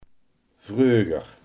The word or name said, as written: vroeger